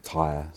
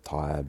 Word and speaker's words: Taia: And your studio name, spelled T-A-I-A. – Yes. – Could you pronounce it? – It's called Tire Studios, which is a Maori name. And we named it Tire